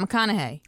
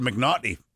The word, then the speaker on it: McConaughey: How do you say Matt McConaughey's name? – Matt McConaughey. – Is that how you say it? – Yeah. – Whew, got lucky on that one. – Why? What is it? – I think I said McNaughty the first like 3 years.